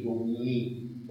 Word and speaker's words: Domini: Pero se tiene que considerar la pronunciación francesa, Pax in nomine Domini, porque este I da luego una rima.